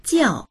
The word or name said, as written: jiao4